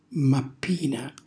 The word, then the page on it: mappina: Calabrese